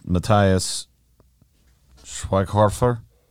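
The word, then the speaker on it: Matthias Schweighöfer: Yeah, so and I am not even going to try to pronounce his last name. It's Matthias Schweighofer. – Yeah. Nailed it.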